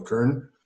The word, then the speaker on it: cairn: And it's the C-A-I-R-N. So it's pronounced as K-E-R-N, like a kern.